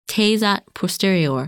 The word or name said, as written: Tejat Posterior